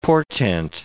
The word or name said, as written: portent